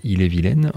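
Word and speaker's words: Ille-et-Vilaine: Alors Il est vilaine, ça s'écrit comme si on disait Il est vilain, mais en fait c'est Il est vilaine en rapport au département de la Bretagne.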